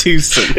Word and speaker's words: Tucson: It's pronounced Tucson.